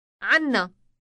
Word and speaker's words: عنا: عنا عنا Note the elongated n sound.